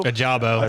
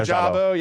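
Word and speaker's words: Ojabo: Was it Adebu or how you pronounce his last name? Adebu? – Ajabo. – Ajabo, yeah.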